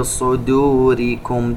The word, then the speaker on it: صدوركم: حاجة في صدوركم، تنوين وبعده حرف الفاء إخفاء، وننتبه إلى ترقيق الدال في كلمة صدوركم ولا ننطقها ضاد.